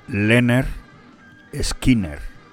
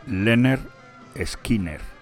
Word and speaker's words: Lynyrd Skynyrd: Estos son teloneros, o fueron teloneros de los Who, y yo antes te he dicho Lynyrd Skynyrd, pero no, tienes que saber que se pronuncia Lynyrd Skynyrd. Incluso hay un disco de ellos que dicen, pronúncialo así, Lynyrd Skynyrd, nada de Lynyrd ni nada de nada, Lynyrd Skynyrd.